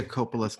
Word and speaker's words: Coppola: According to Sofia Coppola's cousin, it's pronounced Coppola.